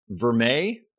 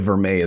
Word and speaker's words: vermeil: Or vermeil? Did I pronounce that correctly? – Yeah.